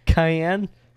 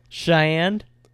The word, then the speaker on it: cayenne: Cayenne? Cheyenne? How do you say it? – Cayenne. – Yeah, cayenne pepper.